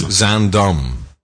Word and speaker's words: Zaandam: That's Zaandam. – Zaandam. – Zaandam.